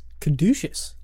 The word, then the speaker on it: caduceus: I did learn how to pronounce the word caduceus. – Caduceus. – Yeah. – What does caduceus mean? – Well, it means caducus, which is how I've been saying it for the past all of my years.